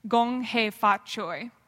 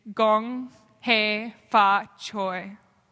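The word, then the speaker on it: Gong He Fa Choi (恭喜發財): I got to celebrate Chinese New Year every year as a girl and learned how to say the proper greeting on that day, Gong He Fa Choi. You can say it with me, Gong He Fa Choi.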